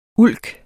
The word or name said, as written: ulk